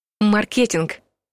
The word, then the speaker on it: маркетинг: В профессиональной среде говорят «маркетинг», так как слово произошло от английского «market», а лингвистам почему-то больше нравится вариант с ударением в середине слова «маркетинг». Словари чаще всего дают эти варианты как равноправные.